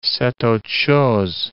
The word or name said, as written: c'est autre chose